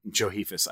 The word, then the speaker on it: Josephus: Another interesting thing about Johe- Josephus, I, I keep saying his name wrong,